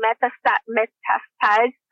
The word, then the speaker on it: metastasize: metastasize. How do you say that? – Metastasize. Yep.